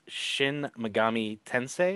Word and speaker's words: Shin Megami Tensei: it was Shin Megami Tensei. Am I saying it right, Tim? – Yes, you're saying it right.